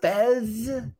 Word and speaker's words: PHES: Sal is the owner of Fez Services, Fez Cleaning Services. You know what's funny is it's P-H-E-S, but when I pronounce it, I think of the foreign exchange student character on that 70s show.